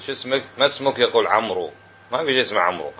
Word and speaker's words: عمرو: هاي الواو فقط لتكون فارقة بين عمرو وعمر، واضح؟ يقال نستعارها من داود، داود فيها واوين، تنطق بواوين وتكتب واو واحدة، وين الثانية؟ أعطاها عمر رحمة به حتى يتميز بها عن عمر، لا يقال عمرو.